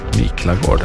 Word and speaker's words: Miklagård: Vilken stad kallade vikingarna för Miklagård? Om jag uttalar det rätt. – Miklagård, ja.